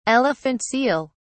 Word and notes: elephant seal: British English

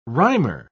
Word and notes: rhymer: British English

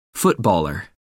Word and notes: footballer: American English

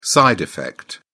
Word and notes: side effect: British English

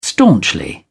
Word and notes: staunchly: British English

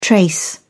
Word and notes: trace: British English

